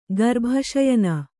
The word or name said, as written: ಗರ್ಭಶಯನ